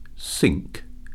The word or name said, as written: sink